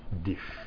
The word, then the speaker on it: DIS: The word we consider here is dish, year, spelled capital D, capital I, capital S, and pronounced dish.